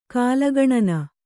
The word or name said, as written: ಕಾಲಗಣನೆ